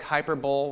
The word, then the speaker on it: hyperbole: Looks like hyperbole when you spell it, but it's pronounced hyperbole.